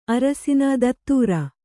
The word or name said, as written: ಧತ್ತೂರ